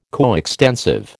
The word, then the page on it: coextensive: American English